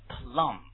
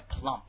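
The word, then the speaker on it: plumb: With plum，这个念 plum，不要念 plump，而且这个 b 不发音啊。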